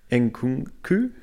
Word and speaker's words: Nkunku: Kimpembe is injured, and as of today, and please help me with the pronunciation, is it Nkunku? – Yep.